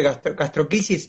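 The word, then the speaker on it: gastrosquisis: De gastroquisis, ¿estoy pronunciándolo bien o...? – Sí.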